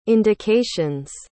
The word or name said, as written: indications